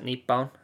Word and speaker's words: Nippon: It's the Japanese series, the Nippon series, as it's called. Because that's what they call Japan over here in Japan. It's called Nihon, but you can also pronounce it Nippon, you know?